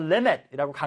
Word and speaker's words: limit: 그래서 limit이라고 강세가 지금 여기 앞에 있죠.